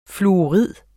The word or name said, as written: fluorid